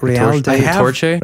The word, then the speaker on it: Real de Catorce: Real de Catorce? – Real de Catorce.